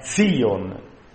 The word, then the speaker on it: Sion: Bisognerebbe pronunciare con una Z dura. È Sion il nome di questo monte.